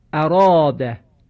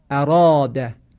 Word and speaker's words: اراده: به عنوان مثال به تلفظ این کلمه توجه بفرمایید: اراده اراده. عنایت دارید که اینجا را مفتوحه صدای کشیده آ بعد از او قرار گرفته. صدای کشیده آ رو ما پرهج و درشت تلفظ کردیم. چرا؟ به تبعیت و پیروی رای مفتوح که تفخیم می شود.